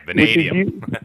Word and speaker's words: vanadium: vanadium. – Vanadium.